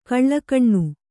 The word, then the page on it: ಘಲಕನೆ: ghalakane